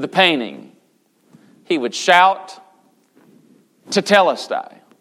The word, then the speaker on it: tetelestai: Now the word Jesus is using here, and you've heard the word I'm sure many times before, it's the word tetelestai. How do you pronounce that word? We'll go with tetelestai.